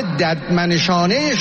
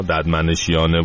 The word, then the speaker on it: ددمنشانه: به به به به، ببین دیگه اینجوری نداشتیم شیخ حسنا، ددمنشیانه و اینا مال آقا جنتی بود، خرابش نکن. ببین چه خوب تلفظ میکنه ایشون، ببین اینا اینجوری تلفظ کن.